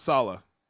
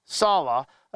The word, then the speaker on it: Saleh: The games are more, you know, everybody thinks they're going to the Super Bowl, even though Robert Saleh, is it Saleh or Saleh? – Robert Saleh. – Saleh. Okay, I keep saying it wrong.